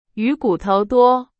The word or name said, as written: 骨头